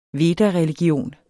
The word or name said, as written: vedareligion